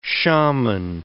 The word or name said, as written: shaman